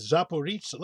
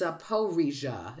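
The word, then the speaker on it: Zaporizhzhia: And I hear it as Zaporizhia. Let me just see. – Okay, everything I see has it as the P-O-H is in capitals, which usually means it's the stressed syllable.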